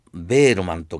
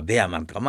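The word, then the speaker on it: Behrman: で何言ってんの、頑張んなきゃって、そのうちにそのベールマンとかベアマンとか、まあこれあの英語の読み方、フランス語の読み方なんだろう、あるいは本によりはベールマンだし、本によりはベアマンだし、老人がね、その油絵で最後の一枚を壁に、それは立体的に、その最後の一枚残って私も生きなきゃと思うと。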